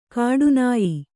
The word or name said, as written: ಕಾಠಿನ್ಯ